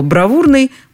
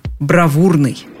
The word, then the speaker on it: бравурный: Помните только, что русское слово «бравурный» произносится именно так – «бравурный».